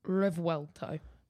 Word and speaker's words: Revuelto: Revuelto.